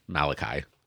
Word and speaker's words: Malachi: Context is going to determine what we're talking about, because you look at the book of Malachi, or English, Malachi. – Mm-hmm. Right? – It's my messenger. – You better say it like that next time you quote it in church.